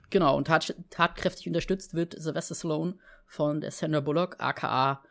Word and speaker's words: Sylvester Stallone: Sylvester Stallone, ganz genau. So wird der nämlich richtig ausgesprochen. Das wissen, ja, das wissen nur die meisten nämlich nicht.